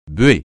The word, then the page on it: buée: francais